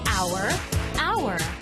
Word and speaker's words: hour: Phonics silent H. Hour, hour.